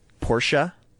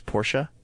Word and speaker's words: Porsche: I also know, no one's correcting me on this, but for the record, I know that it's Porsche. It's not Porsche. It's Porsche. That's what Porsche aficionados will tell you.